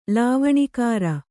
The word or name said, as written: ಲಾವಣಿಕಾರ